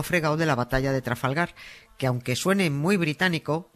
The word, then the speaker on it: Trafalgar: Que no es Trafalgar, que es Trafalgar. Que una cosa es que los guiris no sepan decirlo, que en su derecho están y no pasa nada, pero otra muy distinta, que un español snob lo pronuncie con acento inglés comiéndose todas las R's.